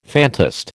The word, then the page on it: phantast: American English